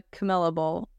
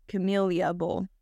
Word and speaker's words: Camellia: It's not the Camilla Bowl. It is the Camellia Bowl. – I thought that's what I said. – You said the Camilla Bowl. – The Camellia Bowl.